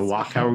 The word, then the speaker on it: Wachau: Katja Scharnagel hails from the Wachau, is that how I pronounce it? – Yes. – The Wachau region of Austria.